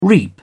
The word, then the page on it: reap: British English